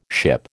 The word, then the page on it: ship: American English